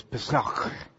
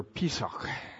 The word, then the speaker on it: Pesach: And when a Jewish person says it, and I looked on YouTube to find out how to pronounce it correctly, when a Jewish person pronounces it, it's more like they're about ready to spit at the end of the word. So it's Pesach. And or Pesach.